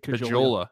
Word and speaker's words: Caggiula: How do you pronounce his name? – Got Caggiula. called up. Drake Caggiula. Man, I was way off. Drake Caggiula. I'm sorry to your family. I I mispronounced that name so bad.